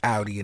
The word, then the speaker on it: Audi: Is it Audi or Audi? – Technically, it's Audi. – It's Audi? – Yeah. All right. We all say Audi. It's okay. – It's about to be Audi in the Audi.